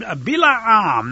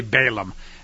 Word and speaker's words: Bilaam: He sent messengers to a fella who was named Bilaam. Now, in a lot of English Bibles, it'll say Balaam. That's what we probably heard, but I prefer the Hebrew pronunciation because that's what his name really was.